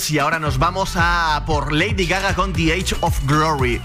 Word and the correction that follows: The Edge of Glory: Madre mía, qué cuadro de pronunciación. Soy un cuadro hablando inglés, así que mejor lo voy a decir como se escribe: The Edge of Glory.